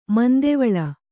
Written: ಮಂದಿವಾಳ

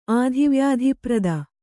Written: ಆಧಿವ್ಯಾಧಿಪ್ರದ

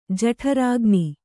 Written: ಜಠರಾಗ್ನಿ

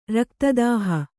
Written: ರಕ್ತದಾಹ

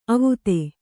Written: ಅವತ್ತು

